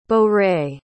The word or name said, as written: bourrée